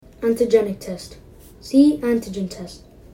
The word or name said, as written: antigenic test